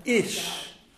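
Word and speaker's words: ish (איש): And that is why we are told that the Hebrew word for man is ish.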